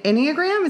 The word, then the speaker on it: Enneagram: Yeah, which was fun when we did an Enneagram. Is that how you say it? – Yeah. Uh-huh.